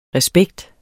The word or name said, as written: respekt